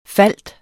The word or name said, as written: falde